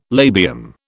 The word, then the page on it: labium: British English